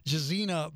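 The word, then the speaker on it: Gesine: When is the book released, Jazenny? – It's out on the 14th, which just happens to be Pi Day. – Oh, that is Pi Day. That's exciting for a baker. – It is. – Jazenny Bullock Prado.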